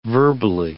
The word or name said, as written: verbally